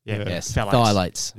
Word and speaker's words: phthalates: P-H-T-H-A-L-A-T-E-S. – Phthalates.